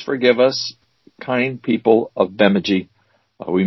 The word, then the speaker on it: Bemidji: It's easy to pronounce it Bemidji, but that would be wrong. – Yeah, I knew that the middle syllable was the one to be accented, but I think just fracture it, and that's what I did. – It's the first one. It's the first one gets emphasized.